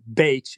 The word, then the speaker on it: Bach: The Bach flowers were developed in the 30s by a guy by the name of Edward Bach, is really the correct pronunciation, B-A-C-H.